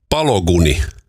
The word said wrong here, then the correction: Balogunin: Folarin Palogunni. No niin, amerikkalainen. Folarin Palogunni. – Folarin Balogunin.